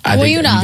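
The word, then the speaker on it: Bayona: And what beware, Bayuna. Is that how you say it? – Yep. Bayuna. – You got it.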